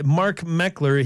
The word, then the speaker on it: Mark Meckler: On the phone with me is uh, Mark Meckler.